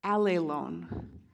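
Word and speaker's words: allelon (ἀλλήλων): And the word that I'm talking about is this weird Greek word. It's pronounced allelon.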